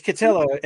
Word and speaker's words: Cotillo: Yeah, it's Cotillo. It rhymes with pillow.